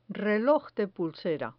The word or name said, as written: reloj de pulsera